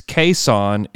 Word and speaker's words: Cason Wallace: But it's Cason is the correct way to say it. And that was, those are his words.